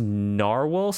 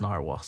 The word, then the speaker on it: Nahvalur: And it is pronounced Narwhal still. – The owner wants it to pronounce Narwhal, so let's respect his wishes and pronounce it Narwhal.